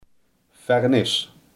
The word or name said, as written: vernis